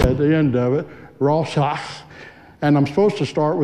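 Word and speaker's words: Roshach (רצח): If anybody had Spanish, you know you have to roll your R's. Well, in the Hebrew word, it was a roshach, and then you clear your throat. That's kind of how you say it.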